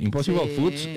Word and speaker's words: Impossible Foods: Impossible Foods, ¿lo dije bien, por favor? – Sí, ¿no? Perfecto. – Muy bien, Impossible Foods, que tenía una valoración, y es una marca de comida saludable, que tenía una valoración de 200 millones de dólares de inicio.